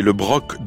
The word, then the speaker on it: Broc: Oui, on prononce le C, le Broc, dont le blason, cette commune, dont le blason est un bro, là on ne prononce pas le C, un bro d'eau.